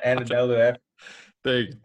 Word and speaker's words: Anadolu Efes: Anadolu Efes? – Anadolu Efes.